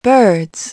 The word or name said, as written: birds